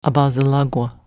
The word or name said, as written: abazolagw